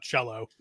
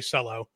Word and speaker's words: Celo: I think it's pronounced Cello, not Cello. Um, so I'm, I'm going to say Cello.